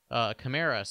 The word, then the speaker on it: Kamara: He talked about the pronunciation of his last name, either Kamara or Kamara. He likes Kamara, so we'll go with Alvin Kamara.